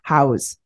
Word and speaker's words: Howes: So Lewis Howes, I keep saying his name wrong. He was in the Andy Frisella podcast. Howes. That's right. H-O-W-E-S.